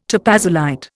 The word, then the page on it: topazolite: Indian